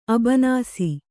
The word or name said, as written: ಅಬನಾಸಿ